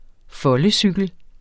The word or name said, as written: foldecykel